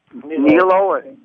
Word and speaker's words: nihilo: How would you pronounce that? – Nihilo, sorry? – Yeah, I think Nihilo. – Nihilo. Creation ex nihilo.